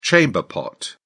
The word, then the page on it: chamber pot: British English